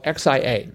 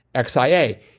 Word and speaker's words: Xia: And the pronunciation was Xia, or actually it was the emphasis was on the ya, Xia. And he actually sent in a link to a YouTube pronunciation of it. So X-I-A is pronounced Xia.